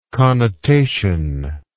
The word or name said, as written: connotively